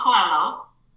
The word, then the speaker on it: Coelho: Paulo Coelho. I don't know if I pronounced his name right. – You did. I love it.